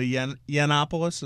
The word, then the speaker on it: Yiannopoulos: Right-wing commentator Milo Yiannopoulos, am I pronouncing that correct? – I think so.